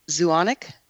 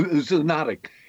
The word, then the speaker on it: zoonotic: What is, you talked about it a little bit earlier, but what's a zoonotic disease? – What is what kind of disease? – I'm trying to pronounce the word. Zoonotic? – Oh, zoonotic.